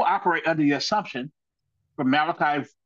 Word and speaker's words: Malachi: Malachi, whose name means messenger or my angel, is actually pronounced Malachi in Hebrew.